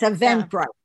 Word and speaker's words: Eventbrite: There will be free Eventbrite, am I saying that right? – Eventbrite? – Yes, Eventbrite. Yeah. That's what it is.